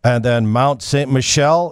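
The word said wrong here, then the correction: Mont Saint-Michel: Mont St. Michel.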